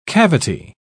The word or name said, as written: cavity